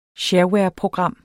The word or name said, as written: SHAREWAREPROGRAM